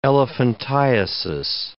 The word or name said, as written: elephantiasis